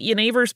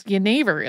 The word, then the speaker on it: genever: Yeah, because Yennefer is spelled G-E-N-E-V-E-R. So if you're reading it on a page as an English speaker, it looks like it's Jennifer. So since common juniper is native to the UK, there were already culinary and medicinal uses for it well before Yennefer was introduced.